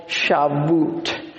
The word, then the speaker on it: Shavuot: Pentecost is actually known by another name among the Jews and the Jewish forerunners of what became Christianity, and it's called Shavuot.